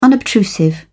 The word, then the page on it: unobtrusive: British English